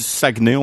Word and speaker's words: Saguenay: Saguenay, Est-ce que je prononce ça bien Saguenay, ouais. On dit Saguenay, on, on Saguenay.